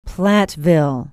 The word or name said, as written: platteville